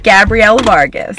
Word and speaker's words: Gabrielle Vargas: Yeah, Gabrielle Vargas.